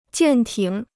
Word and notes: 舰艇: jiàn tǐng

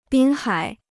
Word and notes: 滨海: bīn hǎi

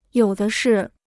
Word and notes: 有的是: yǒu de shì

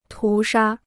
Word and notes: 屠杀: tú shā

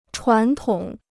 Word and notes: 传统: chuán tǒng

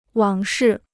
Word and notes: 往事: wǎng shì

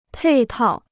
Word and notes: 配套: pèi tào

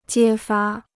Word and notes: 揭发: jiē fā

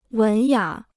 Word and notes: 文雅: wén yǎ

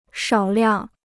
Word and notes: 少量: shǎo liàng

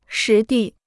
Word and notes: 实地: shí dì

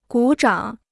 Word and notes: 鼓掌: gǔ zhǎng

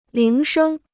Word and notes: 铃声: líng shēng

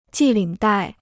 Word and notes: 系领带: jì lǐng dài

